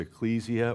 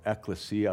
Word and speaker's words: ekklesia: The word is ecclesia or ekklesia, I've heard it pronounced that way, which, you know, it It's where we get our word, you know, ecclesiastical, meaning, you know, church and so on.